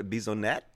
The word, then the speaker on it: Bissonnette: His Twitter account was among a handful of far-right accounts frequented by Alexander Bissonnette.